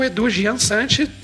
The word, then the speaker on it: Edu Giansante: E nós estamos aí com o Edu Gian Sante. Desculpa se eu pronunciei errado. Não sei, boa tarde, boa noite em qual horário você tá, indo? – Fala, beleza? Esse é, pronunciou correto, tá certo.